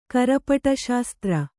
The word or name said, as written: ಕರಪಟಶಾಸ್ತ್ರ